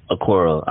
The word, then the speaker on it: Okoro: Okoro, Isaac Okoro.